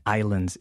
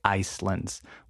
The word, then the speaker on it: islands: Remember, the S in islands is silent, okay? We don't say Icelands. We only say Iceland for the country.